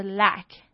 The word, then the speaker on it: lack: 大家不要把它这个字跟湖边这个字搞混咯，湖边是 lake，缺少是 lack， lake， lack， OK，这个发音不太一样。